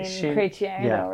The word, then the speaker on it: Chrétien: Chene, I think? – Yeah, that would be Aline Chrétien, right? – Very good.